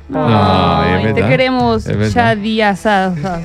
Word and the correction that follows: Shaddai Hadai: A pasarla bien. – Shaddai Hadai. – Grande Shaddai Hadai. – Qué pena ahí por la mala pronunciación.